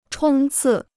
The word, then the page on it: 冲刺: chōng cì